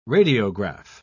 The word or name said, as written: radiograph